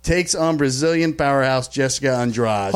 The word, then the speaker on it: Andrade: Well, it's spelled A-N-D-R-A-D-E, but it's pronounced Andrade.